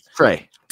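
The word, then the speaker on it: Trea: Is it Trey? It is Trey, right? – Trey. – T-R-A-I. I didn't know if I was saying it right.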